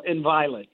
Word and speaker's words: Inviolate: Am I saying it right? Inviolate? Is that the right pronunciation? – Inviolate, yeah.